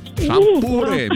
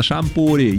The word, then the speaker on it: шампур: Итак, запомните, Олечка, и вы особенно, что везде, как бы вы там ни переворачивали, ударение во всех формах существительного падает на букву «у». Шампуры, металлические шампуры, жарить на шампуре, есть с шампура. Ну и так далее.